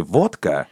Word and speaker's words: водка: Но с другой стороны, э, те же самые иностранцы, например, в слове «водка», они делают очень точное ударение. Они не говорят «водка».